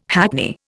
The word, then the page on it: hackney: Indian